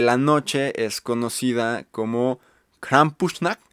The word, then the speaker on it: Krampusnacht: lo googleé y busqué en alemán cómo se dice esto y se dice así. Krampusnacht. Otra vez. Krampusnacht. Así se dice.